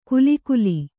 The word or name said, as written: ಖುಲಿಖುಲಿ